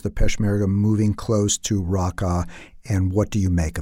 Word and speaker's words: Raqqa: And he was actually following Kurdish soldiers who are now, he reports, I believe he said about 20 miles or so from Raqqa. Is that how you pronounce it, by the way? – Raqqa. – Raqqa.